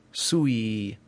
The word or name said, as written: sui